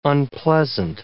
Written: unpleasant

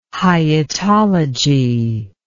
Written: hyetology

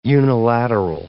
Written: unilateral

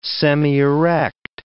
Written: semierect

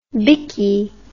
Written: bikkie

